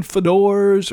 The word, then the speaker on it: Fodor's: I had to look up how to pronounce Fodors. F-O-D-O-R-S. You know, the travel company? Those travel people? I thought it might have been Fodors or Feuders or whatever, but it's just simply Fodors.